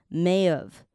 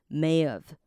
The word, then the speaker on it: May have: But Peter，好，前面这个 P E 哈，它是发 P P P 长音 Peter，好，中间这个 T 呢是的音 Peter Peter，好，那我们来看一下 May have gone，这边这三个字到时候念是会念在一起的，所以 May have 我们要先来学一下 May have 你要怎么念呢？May have 合在一起的时候 H 就会不见了，然后你听我念一次哦，May of May of，好，所以 H 不见之外呢，它的 A 会变成的音 May of。